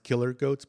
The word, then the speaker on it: Kill Her Goats: It's pronounced Killer Goats, but it's spelled Kill Her Goats.